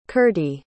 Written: Kirti

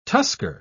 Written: tusker

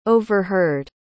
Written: overheard

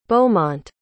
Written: beaumont

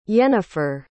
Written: yenifer

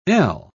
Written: el

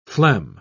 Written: phlegm

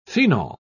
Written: phenol

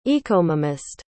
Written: ecomomist

